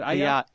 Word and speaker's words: Eyal: Is it Eyal? – Eyal, actually. – Eyal, sorry.